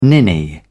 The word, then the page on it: ninny: British English